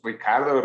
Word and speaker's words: Ricciardo: Aunque siempre he tenido pedo cómo se pronuncia, ¿eh? – yo digo Ricciardo. – Yo digo, yo decía Richardo, güey. Y yo veo que los comentaristas dicen Richardo, güey. Pero ese güey dice que es Ricardo, Ricciardo, un pedo así, güey.